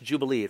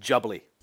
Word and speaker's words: Jubilee: And apparently, it's not pronounced Jubilee, it's pronounced Jubbly.